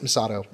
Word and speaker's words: Misato: and the immediate command center and Misago, I'm probably mispronouncing yeah, that, even though I just Misato. There we Misato.